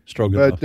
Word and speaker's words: Stroganoff: It's spelled, the Russian spelling is Stroganov. – Stroganoff. – But the English, we do it Stroganoff, which is phonic, Stroganoff.